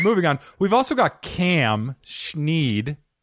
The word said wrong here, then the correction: Sneed: Sneed. – Hey, guys. – Sneed.